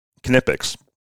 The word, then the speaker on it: Knipex: Specifically, Knipex. I am saying that right. I checked.